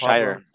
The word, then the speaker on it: Al-Shaair: Al-Shaair.